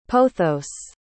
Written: pothos

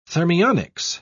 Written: thermionics

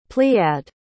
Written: pleiad

